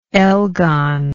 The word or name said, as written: elgon